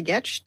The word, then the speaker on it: Getch: And Sally Getch rhymes with sketch, the last guests to introduce themselves, and then I can get into the content today. – I'm Sally Getch, WP Fangirl, organizer of the East Bay WordPress Meetup.